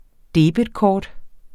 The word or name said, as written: debetkort